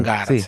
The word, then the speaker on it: Vangarde: Bueno, Daniel Valgarde, ¿lo dije bien? ¿Valgarde? ¿Está bien? – Valgarde, sí.